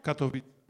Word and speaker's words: Katowice: To Kolowice? You say it like that? – Katowice. – Oh, excuse me.